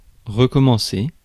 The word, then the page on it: recommencer: [ʁə.kɔ.mɑ̃.se]; /ʁkɔ.mɑ̃.se/